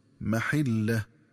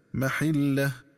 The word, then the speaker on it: محلة: وكذلك ينبغي التنبه للنطق الصحيح للفظ محلة، فيقرأ بكسر الحاء هكذا محلة.